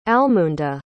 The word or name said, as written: almunda